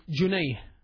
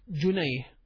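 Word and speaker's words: junayh (جنيه): The pound, it's called junayh. Pound is junayh. Jim noon ya ha. Pound, junayh.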